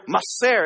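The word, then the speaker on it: maser (מעשר): You kind of have to say it like you got some a loogie in the back of your throat, like a maser kind of thing.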